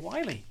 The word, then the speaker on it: Wiley: I do believe that it's Mr. Wiley. Rhymes, you know, who's up next? Rhymes with Smiley.